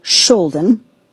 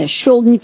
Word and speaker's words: Skjolden: In Norway, I was all the way up, don't laugh at my pronunciation, in a town called Skjolden, which is spelled with a K. Skjolden has a K in it.